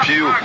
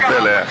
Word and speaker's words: Pelé: sem saber eh, pronunciar o nome Pelé, porque eles falavam Pio, Pili, qualquer coisa que eu nem entendia direito o que era, e era pro Pelé.